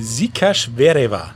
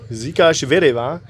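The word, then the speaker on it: Székesfehérvár: Ähm, die ersten, die ersten drei Mannschaften, die, die dazugekommen sind, waren Zürich, Mailand und ich sag den deutschen Namen, Stuhl Weißenburg und du nennst den die, die Original-Pronunciation bitte. Ich versuch's, das ist nämlich Sikash Vereva. Sikash Vereva.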